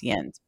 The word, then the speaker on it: Jens: And uh, so the um, his name is Jens Grede, and or, or sorry, Jens. It's pronounced Jens, but he's Swedish.